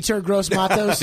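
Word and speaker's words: Yetur Gross-Matos: Eter Gross-Matos.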